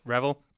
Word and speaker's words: Revel: Now, today I'm here with my executive producer, Channa Rifkin, and Professor Ariel Revel. I'm sorry, I am mispronouncing your name. I don't speak. – It's Revel. – Revel.